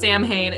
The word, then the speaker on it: Samhain: But the holiday that is spelt like Samhain is not Samhain, it's Samhain.